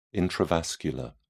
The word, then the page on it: intravascular: British English